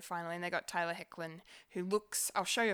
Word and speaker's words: Hoechlin: No, I never knew how to pronounce his name until recently. But yeah, it's Tyler Hecklin. – Hecklin. Okay. – Yeah, it's a weird spelling.